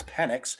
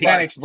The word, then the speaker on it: Penix: I'm pretty sure it's Penix. – I think it's Penix too.